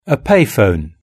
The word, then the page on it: a payphone: British English